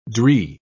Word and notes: dree: British English

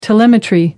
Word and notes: telemetry: American English

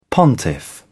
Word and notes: pontiff: British English

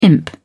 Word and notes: imp: British English

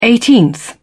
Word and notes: eighteenth: British English

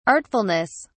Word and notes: artfulness: British English

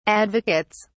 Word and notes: advocates: British English

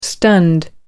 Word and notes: stunned: American English

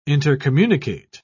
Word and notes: intercommunicate: British English

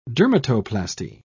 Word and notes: dermatoplasty: British English

